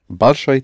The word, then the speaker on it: Большой: Большой театр. – Mm-hmm. Accent ой in the ending.